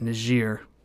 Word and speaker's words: Niger: It is properly pronounced Niger since up until the 1960s, I believe, it was a part of France, I'm pretty sure. So, and the language in this country is still French.